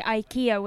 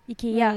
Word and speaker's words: IKEA: Yeah, I mean, people usually say IKEA, which is not very close to, it's IKEA. Mm.